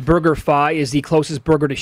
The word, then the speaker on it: BurgerFi: Kevin Rogers of Vegas Insider says pronounced like Wi-Fi, BurgerFi.